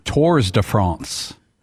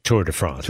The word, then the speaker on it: Tour de France: But you don't pronounce the S. – The S in Tours. – Yeah, so it's Tour de France.